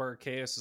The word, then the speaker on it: Arceus: Because it goes with Deus. – That makes a lot. – Latin for God. So. – Okay. So I will continue using Arceus, but Arceus is the proper pronunciation.